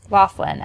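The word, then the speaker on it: Loughlin: Laughlin? – Laughlin, as Rebecca Donaldson, who is Uncle Jesse's wife.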